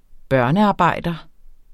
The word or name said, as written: børnearbejder